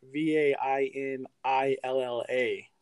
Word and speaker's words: Vainilla: The bottle is spelled Vainilla, V A I N I L L A for vanilla, but it's Vainilla, like Bridezilla, but instead of a bride, yeah, you're somebody drinking something fancy.